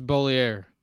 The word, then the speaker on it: Bollier: It's Bollier. – It looks like it says Balieu. – French.